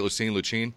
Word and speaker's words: Lucene: Lucene? – Yeah.